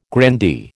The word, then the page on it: grandee: American English